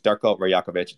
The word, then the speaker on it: Darko Rajkovic: Darko Rajkovic. It's silent J.